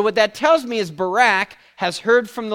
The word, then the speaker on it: Barak: And it says, for she sent and called for Barak, or Barak is really the right pronunciation of that.